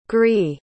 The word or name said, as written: gwri